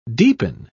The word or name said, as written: deepen